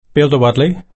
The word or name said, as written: piltawodli